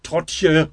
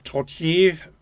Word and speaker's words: Trottier: Die Hiesigen haben es gerne immer auf der ersten Silbe betont und schleppten die letzte Silbe dann gerne mit, so wie Trottje oder so ähnlich. Aber mein Vater hat das also immer schon sehr ordentlich betont.